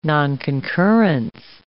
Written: nonconcurrence

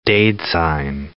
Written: daidzein

